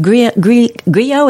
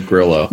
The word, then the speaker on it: Grillo: is that how you say it? Uh, I think that's probably the proper pronunciation, but everybody else just says Grillo. – Grillo. Grillo. Okay, Frank yeah. Grillo. Okay, I was doing the uh, the other pronunciation, and and just a great cast.